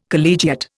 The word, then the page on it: collegiate: Indian